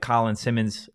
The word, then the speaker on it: Colin Simmons: Obviously, a guy like Colin Simmons, he is, you know, the number one edge guy. Did I fuck up his name again? – No, you said it right.